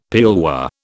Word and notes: pearlware: British English

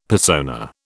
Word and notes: persona: British English

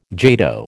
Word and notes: jato: American English

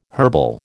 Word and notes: herbal: American English

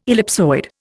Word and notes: ellipsoid: Indian

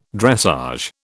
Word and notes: dressage: British English

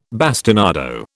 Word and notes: bastinado: British English

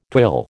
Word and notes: twill: American English

